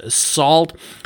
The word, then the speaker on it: NaCl: The traffic is encrypted, taking advantage of the Network and Cryptography Library, or short NACL, or actually pronounced SALT, to implement the TOX protocol.